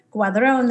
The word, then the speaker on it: Guadron: So it's Gloria Guadron, that's spelled G-U-A-D-R-O-N.